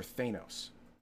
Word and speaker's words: Thanos: And on Garthon's comic poll featuring Cthulhu Law, we have Detective Comics number 1002, Thanos number 1, or do you do you pronounce it Thanos or Thanos? – Thanos. – Thanos. Okay, Thanos number 1 and from Image Comics, Criminal number 4.